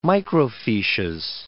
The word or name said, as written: microfiches